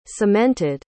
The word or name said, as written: cemented